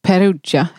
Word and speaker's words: Perugia: Hon ville ha Perugia, heter det så nu då? Är det rätt? Okay. – Super, toppen uttal.